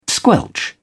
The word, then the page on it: squelch: British English